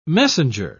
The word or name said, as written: messenger